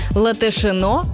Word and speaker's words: Латышино: Латышино произносится именно так, а вовсе не с ударением на второй слог, как любят многие.